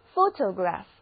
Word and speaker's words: photograph: 第一个字母 o 发它的本音 o，而第二个字母 o 则读作 a， photograph，它的简写形式就是 photo，这个时候两个字母 o 都发它的本音 photo。